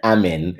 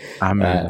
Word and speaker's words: Amin: It's Amin. Amin.